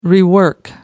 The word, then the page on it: rework: American English